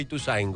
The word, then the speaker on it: Ituzaingó: ¿Cómo se dice? ¿Ituzaingó o Ituzaingó? – Para mí es Ituzaingó. – Ah, no estamos. – En la O.